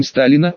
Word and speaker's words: Сталина: «Не стесняйся, Альбиночка», — сказала женщина, сидевшая за соседней кассой, со странным именем Сталина, ударение на второй слог.